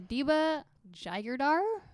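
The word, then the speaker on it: Adiba Jaigirdar: By Adiba Jayadar. – Okay. – I'm going to tentatively say that's how it's pronounced. – Yep.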